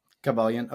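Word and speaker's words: Kybalion: And this is something I read the and I don't even know how to pronounce it, so maybe you can do it for me, but it's like the Kabbalion from Kabbalion. – Okay,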